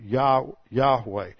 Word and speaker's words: Yahweh: So you have, we have a pretty good understanding of this, and based on phonetics and other things, it's suggested that the ending at the last syllable is probably a short E sound, Yahweh.